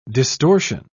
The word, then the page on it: distortion: British English